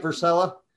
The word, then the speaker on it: Versella: Do I say that right, Versella? – Yes. Got it right on the first shot.